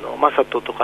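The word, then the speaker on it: 理人: 野球選手で吉井という選手がいるんですけれど、同じく理科の理に人と書いて、あちら、正人と読むので、マサトとかミチトとかと呼ばれることが多いですね。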